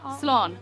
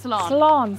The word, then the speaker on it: Slán: Slán. – Slán. – Yes. It was it. Slán.